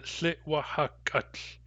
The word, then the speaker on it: thitwahakut: So when we add the ending ut to thitwahuk, we get thitwahakut. The syllables are thi, wa, ha, gut.